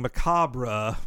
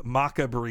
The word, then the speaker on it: macabre: And I just got that that's Bart mispronouncing macabre with macabre. – It's actually macabre.